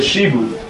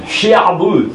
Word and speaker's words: shibud: In Hebrew, in the language of the Torah, it's called a shibud. – Shibud. – Shi'abud is the way the Sfarad name would pronounce. You have to pronounce the I, especially if you're a Yemenite. Shi'abud.